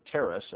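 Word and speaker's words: teras: We're going to look at the word wonder, which is almost invariably teros, teros, or teres, actually, I guess is the better pronunciation, which means a prodigy, an omen, or a wonder.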